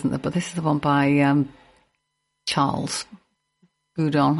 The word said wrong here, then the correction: Gounod: Gounod, not Goudon. Gounod or Gounod. Probably Gounod. Charles Gounod. Let's say that.